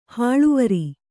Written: ಹಳವಾರ

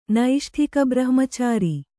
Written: ನೈಷ್ಠಿಕಬ್ರಹ್ಮಚಾರಿ

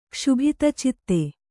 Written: ಕ್ಷುಭಿತಚಿತ್ತ

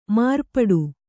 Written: ಮಾರ್ಪಾಟು

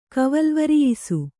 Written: ಕವಲ್ವರಿಯಿಸು

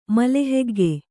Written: ಮಲಹೆಗ್ಗೆ

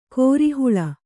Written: ಕೋರೆಹಲ್ಲು

